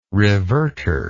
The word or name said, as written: reverter